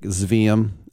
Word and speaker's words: Sveum: spelled like Zveum, S-V-E-U-M, Dale Sveum.